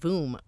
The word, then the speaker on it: Hvoom: Just like his name, he spelled Hvoom with a silent H.